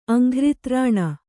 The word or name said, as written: ಅಂಘ್ರಿತ್ರಾಣ